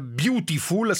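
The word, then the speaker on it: Biutiful: E allora eccoci a questo punto a parlare dell'altra novità in sala di questo fine settimana, Beautiful, scritto proprio così come si pronuncia in italiano, si pronuncia anche in inglese, Beautiful, quindi scritto con la I, perché?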